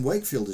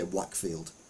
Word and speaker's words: Wakefield: But saying Wakefield is pronouncing it wrong, you should say Whackfield.